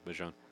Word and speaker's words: Bijan: But someone as young and as fast and talented, you know, Bijan Robinson, or did I say it right? – Yeah, Bijan.